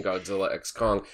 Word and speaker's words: Godzilla x Kong: The important meat of this is how to pronounce the X in Godzilla X Kong.